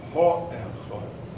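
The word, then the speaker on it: HaEchad: And all the two or three Pesukim are the same, except for that one hay in front of the word Echod, and it's pronounced Ha-Echad in our Sedra.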